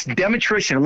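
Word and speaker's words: Dmytryszyn: Yeah, it's Demetrician.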